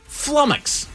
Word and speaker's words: flummox: Flummox. – Flummox. – The word is flummox. – Oh, I am flummoxed. – You are flummoxed. Very good. – He's flummoxed. Rhymes with stomachs.